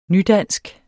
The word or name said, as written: nydansk